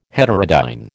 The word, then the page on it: heterodyne: American English